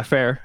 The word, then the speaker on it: Fehr: So anyway, yeah, Fair is how it's pronounced.